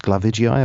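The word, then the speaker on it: globigii: coli, and the other one that you've mentioned was Bacillus glabigii, if I said that correctly. – Glabigii. Yes.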